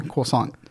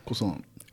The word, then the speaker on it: croissant: The British pronounce it the French way. – Yeah. – Oh. – Croissant. – Croissant. – But Americans say croissant. – Yeah, but he because it's spelled fucking croissant.